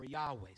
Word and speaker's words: Yahweh: We pronounce it as Jehovah or Yahweh.